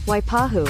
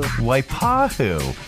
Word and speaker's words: Waipahu: How do you pronounce that? Waiapu? It's in Hawaii. And here, let's get a pronunciation key. How to pronounce... Oh, it's her. It's our Pronounceify lady. – Waipahu. – Waipahu. It's Waipahu.